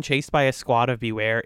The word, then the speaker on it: Bewear: Beware. Which is spelled B-E-W-E-A-R. But it's homophonic with beware, the word.